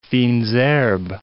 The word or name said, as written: fines herbes